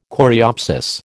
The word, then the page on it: coreopsis: American English